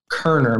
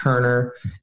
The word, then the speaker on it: Koerner: My name is Matt Koerner. My last name is Funky. It's spelled K-O-E-R-N-E-R. Matt Koerner.